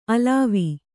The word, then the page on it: ಅಲವ: alava